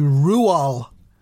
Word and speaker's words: Roald: The correct pronunciation of Roald is actually Rual, and the D at the end remains silent as the grave.